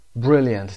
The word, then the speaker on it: brilliant: brilliant تلفظش brilliant، شوا داریم brilliant، brilliant اینو نمیخونیم، brilliant adjective هست.